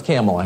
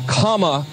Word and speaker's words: Kamala: Her name is pronounced comma, like the punctuation mark. – Comma. – Kamala.